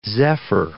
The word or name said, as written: zephyr